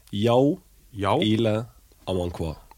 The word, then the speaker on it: Yao Ile Amankwa: Men vi ta riktig uttale en gang til for de. – Yao Ile Amankwa.